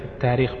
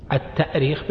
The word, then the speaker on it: التأريخ: أيهما أصح في الكتابة والنطق في كلمة التاريخ بالهمزة والتأريخ؟ طبعا من حيث الأصل الاشتقاقي التأريخ بالهمز، لأنها أرخ يؤرخ، الهمز أصلي فيه، أرخ يؤرخ تأريخا. وأما